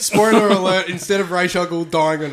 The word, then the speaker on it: Ra's al Ghul: His pronunciation should be Ra's al Ghul, because that's how it was in the animated series.